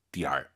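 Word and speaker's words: 点儿: 재밌는 건 이 얼화를 빼면 디엔입니다. 디엔. 얼화를 집어넣으면 DR이고요. 바뀝니다. 발음이. 디엔. DR. 꼭 기억하세요.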